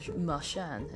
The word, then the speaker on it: Marchand: And last season, you know, like if you were watching something like Nezen for Bruins games and that, the the commentators were going Marchand, pronouncing the D and stuff, but Marchand has kind of said, well, no, it's it's more silent than that, and actually did a video of how to pronounce it.